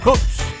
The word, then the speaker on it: Houxx: H-O-U-X-X. Ahí está, Hoots, Hoots.